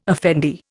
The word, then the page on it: effendi: Indian